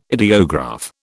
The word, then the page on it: ideograph: British English